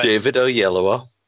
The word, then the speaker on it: David Oyelowo: David Oyelowo.